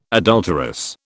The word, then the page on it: adulterous: British English